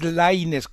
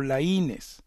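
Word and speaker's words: Laínez: Es Lainez con acento en la A, Diego Lainez, no Lainez.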